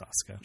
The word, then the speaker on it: Asuka: Yeah, it's Asuka.